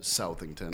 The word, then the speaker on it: Southington: The reason why it's called Southington, but the residents pronounce it Southington, is that Southington actually used to be Farmington, Plainville, and Southington all used to be one big town.